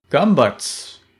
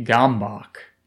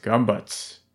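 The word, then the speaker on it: Gömböc: Gumbuts is a Hungarian word, and it's spelled in a way that if you are just an English speaker with no knowledge of Hungarian, looks like it would be Gombach, because it's spelled G O with an umlaut M B, another O with an umlaut C, but it's pronounced Gumbuts, and it is a 3D shape.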